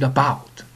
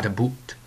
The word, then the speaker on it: about: And I pronounce it about, not a boot.